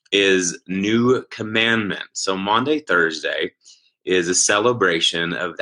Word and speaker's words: Maundy: What is M-A-U-N-D-Y, Maundy Thursday? Okay, now I'm going to give you a little lesson here. So Maundy, that word, it's not Monday, it's Maundy, and it comes from the word mandatum.